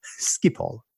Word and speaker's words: Schiphol: And I would just do the stumble and then they say, actually, sir, this is how we pronounce Schiphol. Schiphol.